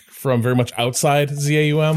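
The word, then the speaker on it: ZA/UM: Is that how we pronounce it? I've never had to like actually say it out loud before. – Well, the slash makes Zaum impossible, right? – I say Zaum. – Yeah, Zaum. Zaum.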